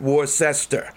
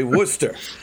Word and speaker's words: Worcester: By the way, down here we pronounce it Worcester, but up there we say Worcester.